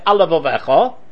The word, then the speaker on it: al levavecha: Now if you say it quickly, you'll say al levavecha, right? And therefore, it doesn't sound like there's two lamas.